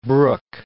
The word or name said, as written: Brooke